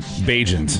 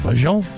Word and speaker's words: Bagent: How do you say his last name? Tyson what? Bagent. Bagent. I Yeah. couldn't tell when he came. I, was, I, I just call it, kept calling him Bajon.